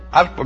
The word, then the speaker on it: Harpo: Arpo, a mí me cuesta trabajo decir arpo porque en inglés, como la H, se pronuncia como J, ¿verdad?